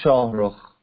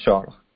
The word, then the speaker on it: Shahrukh: My name is Shahrukh. My name is Shahrukh. It's a little hard to pronounce.